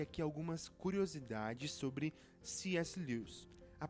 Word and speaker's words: Lewis: E aqui também cabe falar que eu acostumei a chamar Lewis de Lewis, é, mas se você for ver a pronúncia correta lá em inglês, seria algo como Lewis.